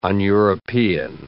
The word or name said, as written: un-European